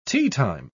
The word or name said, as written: teatime